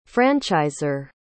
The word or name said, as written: franchisor